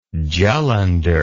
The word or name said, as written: jullundur